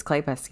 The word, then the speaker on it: Kleypas: So we read Lisa Kleypas's I did say that right? – I think it's Kleypas, yeah.